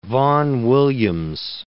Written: Vaughan Williams

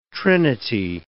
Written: trinity

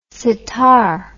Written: sittar

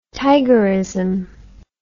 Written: tigerism

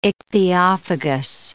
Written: ichthyophagous

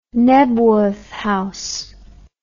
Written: knebworth house

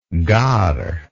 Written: gard

